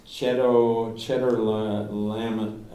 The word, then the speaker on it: Chedorlaomer: Chedorlaomer.